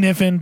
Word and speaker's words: Kniffen: It's Wayne Kniffen, but it has a K at the... It starts with a K. – The K is silent.